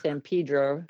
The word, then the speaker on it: San Pedro: Well, if they know what they're talking about, they do know it's called San Pedro. – I thought it was San Pedro.